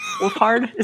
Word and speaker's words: Wolfhard: Wolfhard. Is that how you say it? Wolfhard, right? – Yeah, it's Wolfhard. Wolfhard, yes.